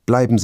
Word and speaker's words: bleib: Bleiben Sie bitte stehen. – Und nun noch ein Tipp. Am Wortende spricht man das B härter aus. Dann klingt es fast wie ein P.